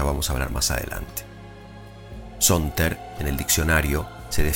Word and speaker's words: saunter: Luego de mucho buscar, encontré la palabra Sonter. Se escribe S-A-U-N-T-E-R, Sonter, que cuando la leí me pareció a simple oído encantadora.